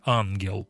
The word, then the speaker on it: ангел: Допустим, слово «ангел» сокращается до «аггел», а между двумя «г» ставится вот это титла и читается уже как «ангел». А если титла нет, то читается «аггел» и имеет уже значение «бес», «демон».